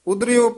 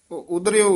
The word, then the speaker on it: उदरियो: इसे तरह उच्चारण करना ओह ढोवे ढोर इति ठमक हाथ चम चमरे इति विश्राम हर इथे यमक ला देनी है उदरियो परियो सरना। उदरियो उच्चारण करना हर उदरियो परियो सरना।